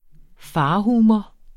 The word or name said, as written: farhumor